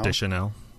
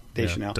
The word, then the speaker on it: Deschanel: Deschanel. – Deschanel.